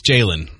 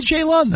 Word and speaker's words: Jalon: J-A-L-O-N. – I know, I think it's Jaylen. – It is. – They always said Jaylen, yeah. – Why do you say Jaylon then? – Because it's J-A-L-O-N. – It's spelled that way.